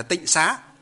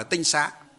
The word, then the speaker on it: tinh xá: Chữ tinh xá. Sau này họ đọc là tịnh xá. Họ đọc là tịnh có nghĩa là sạch sẽ. Nhưng thực tế trong nó bước đầu gọi là tinh xá.